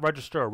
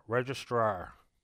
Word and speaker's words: registrar: And it's not register, registrar. – Okay. How do you spell that? R? – R-E-G. – R-E-D? – R-E-G, as in George. R-E-G-I-S. – Yeah. – T-R-A-R, registrar.